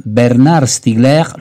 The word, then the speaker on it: Bernard Stiegler: Bernard Stiegler, lo pronuncio alla francese, nel segno di Epimeteo.